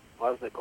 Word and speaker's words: Wozniak: Oh, boy, how do you pronounce that name? Is that Wadnick? – Shane Rademacher will come in and pitch from center field. You bet. Wozniak? – Yep. Wozniak will go to center field.